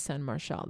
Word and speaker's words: San Marcial: It, as far as I know, it's pronounced San Marcial. That's how my grandparents pronounced it.